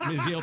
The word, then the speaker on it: Menzel: Oh, it's pronounced when, when I mentioned that, Menzel, thank you.